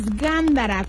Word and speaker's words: gándara: Nos vamos a ir con la palabra rara del día de hoy, y la palabra es gándara, con acento en la primera A.